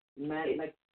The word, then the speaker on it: Mannaz: It's spelled M-A-N-N-A-Z. – Manaz. – Yes, but it's pronounced M-A-W-N-A-H-Z. – Mal-naz. Like mayonnaise, mal-naz. – No, mal-naz.